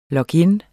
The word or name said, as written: log-in